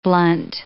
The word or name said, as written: blunt